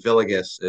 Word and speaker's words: Villegas: And then Villegas is like the Americanized version, but it's Spanish.